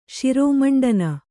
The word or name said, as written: ಶಿರಮುಂಡನ